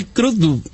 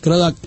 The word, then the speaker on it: Crudup: It's not ducked. Crudup. – Crudup.